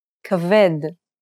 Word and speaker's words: Kaved: Let's take a look at some words that are stressed on the last syllable. Try repeating so that you can get a feel for the rhythm. – Davar. Kaved. Lavan.